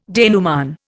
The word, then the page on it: denouement: Indian